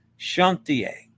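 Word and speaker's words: Chantiers: The final S is silent. Chantier.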